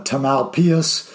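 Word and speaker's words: Tamalpais: Uh, Redwood, obviously, I think has uh, you know Marin Catholic, um, uh, Tamal Pius. Have, I'm sure I just butchered that. – Oh, you uh, killed it!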